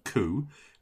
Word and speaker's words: Khoo: And it's called So You Want to Be a Writer podcast, and it's hosted by Valerie Koo, which is spelled K-H-O-O, and Alison Tate, a couple of Australian women.